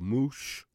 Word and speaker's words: Mooche: And uh, the track is called Moosh Moosh, written by Booker Ervin. M O O C H E. You pronounce it the way you feel like it, but I say moosh, moosh.